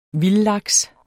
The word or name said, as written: vildlaks